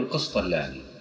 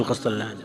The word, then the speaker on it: القسطلاني: ألحظ أخواني فضيلة الدكتور هذه يعني على الجانب نأخذها عن نقطة لطيفة، أنكم دائما تقولون القسطلاني في دروسكم، هو اسمه هكذا؟ – نعم القسطلاني، القسطلاني، نعم نعم هذا الضبط. – لكن الناس ينطقون القسطلان؟ – لا لا، بالتشديد، بتشديد اللام، نعم.